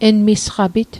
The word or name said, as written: emisqapit